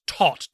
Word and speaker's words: Toht: But his name is Tot, T-O-H-T, pronounced like Todd, the German word for death.